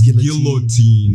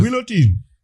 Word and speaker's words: guillotine: Pronunciation might vary as well. – So it's guillotine. Yeah. If you want to, like, pronounce it, like, as it is written. – But guillotine.